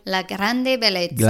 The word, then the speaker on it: La grande bellezza: Just before people get angry at me, how do you pronounce this properly? – La grande bellezza. – La grande bellezza.